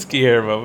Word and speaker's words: Guillermo: You never pronounce his name right. It's always funny. – Guillermo, yeah. – Guillermo. – Guillermo. I don't know. I don't know how to say his name. – It's Guillermo, but yeah, anyway.